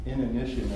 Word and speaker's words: inanition: Inanition, I think, is the way you pronounce it, and I had to look up what it meant.